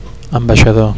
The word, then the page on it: ambaixador: Catalan